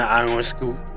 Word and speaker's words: Ionescu: Sabrina Ionescu.